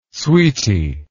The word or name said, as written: sweetie